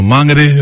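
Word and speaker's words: Māngere: But yeah, grew up in Mangere, or Mangere, if you want to pronounce it maybe properly.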